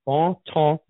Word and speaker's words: Bontemps: There's a place that's been open for probably about 3 years now, Bonton Grill, and it's spelled B-O-N-T-E-M-P-S, Bonton Grill.